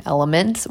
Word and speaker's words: LMNT: Specifically, I like the brand Element, which is spelled L-M-N-T.